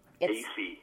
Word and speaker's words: Aiesi: Acey. – It's spelled A-I-E-S-I, and I'm with the Myrtle Beach Police Department here in South Carolina.